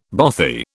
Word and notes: bothy: British English

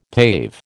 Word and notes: pave: American English

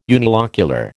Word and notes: unilocular: American English

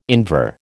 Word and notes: Invar: American English

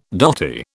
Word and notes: dotty: British English

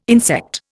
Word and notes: insect: Indian

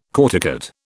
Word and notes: corticate: British English

